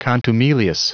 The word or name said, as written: contumelious